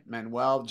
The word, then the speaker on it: Manuel: And it's just my name, Dai Manuel. Just remember, it's spelled D-A-I and then Manuel, M-A-N-U-E-L.